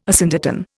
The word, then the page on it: asyndeton: Indian